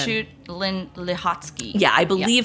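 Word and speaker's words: Margarete Schütte-Lihotzky: Margaret Schutz-Lahotsky. – That is the correct pronunciation. It doesn't quite roll off the tongue if you're not a German speaker, but it's interesting.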